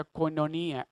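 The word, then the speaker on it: koinonía: Cuando estamos en esa unidad que de acuerdo a la palabra de Dios, como fue escrita la palabra de Dios, se pronuncia cononía,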